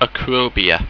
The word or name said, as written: acrobea